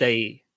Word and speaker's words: Daae: Oh, D-A-A-E? – Yeah, so night is spelled normally, yeah. But it's day.